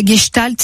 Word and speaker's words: Gestalt: Les gestaltiens, c'est-à-dire des gens qui font de la gestalt, c'est une méthode un peu en psychothérapie. Ça s'écrit GESTALT. Gestalt.